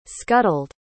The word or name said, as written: scuttled